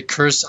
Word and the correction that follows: Kurzweil: I don't know how to pronounce his name. – Well, it's Ray Kurzweil. Yeah, it's Ray Kurzweil.